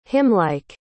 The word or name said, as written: hymnlike